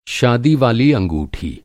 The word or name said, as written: अंगूठी